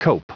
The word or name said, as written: coup